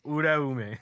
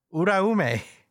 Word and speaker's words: Uraume: Uraume. – Uraume. – No, like roll the R. – Uraume. – There you go. That's much better.